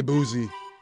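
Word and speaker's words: Boosie: My bad. I didn't realize there was a T in there. – There isn't. – Oh, wait. There's not a T, but you pronounce a T? – I've never heard anybody say Boozy. – It's B-O-O-S-I-E.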